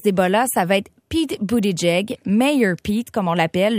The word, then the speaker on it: Buttigieg: La bonne façon, c'est Buttigieg. – Oui, ben Buttigieg.